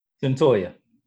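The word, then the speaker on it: Cyntoia: Her name is Centonia. Did I say that right? – Centoya.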